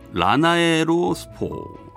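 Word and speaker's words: 라나에로스포: 듀엣 라나 라나에로 스포. 예, 이게 제가 발음이 잘 안 돼. 라나에라 아, 죄송합니다. 라나에로 스포.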